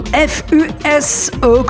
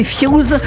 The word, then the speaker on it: F.U.S.E.: Ça faisait grosso modo 15 ans que nous n'avions pas vu ce nom F.U.S.E, que l'on peut aussi prononcer F.U.S.E,